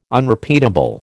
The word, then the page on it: Unrepeatable: American English